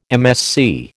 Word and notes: MSC: American English

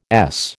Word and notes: Es: American English

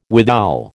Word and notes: withal: American English

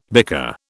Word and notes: bicker: British English

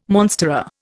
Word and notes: monstera: Indian

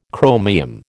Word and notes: chromium: American English

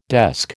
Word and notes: desk: American English

